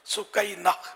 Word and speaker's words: سکینہ: تو یہ ہے اس کا اصل تلفظ سکینہ۔